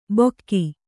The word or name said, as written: ಬೊಕ್ಕಿ